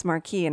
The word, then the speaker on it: Marquis: In French it's Marquis, and that's how I always pronounce it, but I think it's technically Marquess in English, but he's going to be joining the House of Lords, and there's possibly people out to murder him.